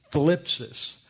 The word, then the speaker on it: thlipsis: The word tribulation in Greek is thlipsis. That's spelled T-H-L-I-P-S-I-S, thlipsis.